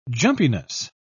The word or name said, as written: jumpiness